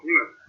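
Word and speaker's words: meme: un mime, c'est écrit M-E-M-E, e, euh, mais ça se prononce mime.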